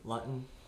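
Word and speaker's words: Luton: Jaguars had a 6th round quarterback, Jake Lupton, I believe. – Lutton. – Yeah, I heard there was a lot of discussion on how you say his name there. – Yeah, it's Lutton, I think.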